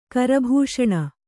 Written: ಕರಭೂಷಣ

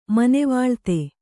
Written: ಮನೆವಾಳ್ತೆ

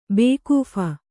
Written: ಬೇಕೂಫ

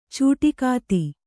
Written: ಚಿಟುಕೊತ್ತು

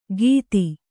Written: ಗೀತಿ

